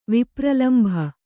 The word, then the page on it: ವಿಪ್ರಲಂಭ: vipralambha